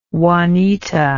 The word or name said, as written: juanita